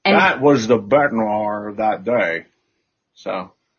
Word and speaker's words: bête noire: Bête noire. – Bête noire. – It's pronounced B-E-T apostrophe N-W-A-R, if that was phonetically spelled, and it's accurately spelled. It's a French term, B-E-T-E-N-O-I-R-E.